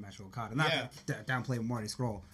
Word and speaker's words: Scurll: I think Marty Scurll, am I saying that right? – Yes.